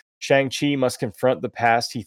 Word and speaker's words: Shang-Chi: Yeah, Shang-Chi.